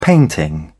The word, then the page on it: painting: British English